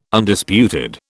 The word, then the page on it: Undisputed: British English